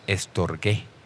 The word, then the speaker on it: storgé: Y luego, había otra palabra que se pronuncia estorgé, y era una palabra para la amistad, la clase de amor personal que uno tiene con otros cristianos.